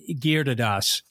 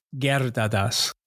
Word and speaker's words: Giridharadas: Well, how would you pronounce the name? Go ahead. – Anand Giridharadas. – Giridharadas. All right. I'm getting closer.